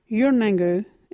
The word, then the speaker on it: Yurnangu: Yonango is spelt Yurnangu. Can you say Yurnangu?